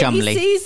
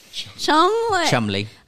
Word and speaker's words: Cholmondeley: Another page of honor is Lord Oliver Cholmondeley, and I'm going to, Lady Chumley. – Chumley.